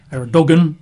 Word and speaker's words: Erdogan: His name is President Erdogan, which you spell as E-R-D-O-G-A-N, Erdogan, but pronounced Erdogan.